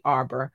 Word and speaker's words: Arbor: But let's get into this Cardi B and Nicole Amber. Or at Arbor, let me say her name correctly, Arbor, situation.